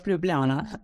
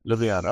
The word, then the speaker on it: Ljubljana: How do you pronounce the university's name properly? Sorry. – It's the University of Ljubljana. – Ljubljana.